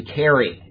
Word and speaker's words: Cery: I've been saying Siri. His name is spelled C-E-R-Y. It's actually Kerry. He's in England, and I didn't know that they pronounce C-E with as a K.